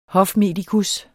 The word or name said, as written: hofmedikus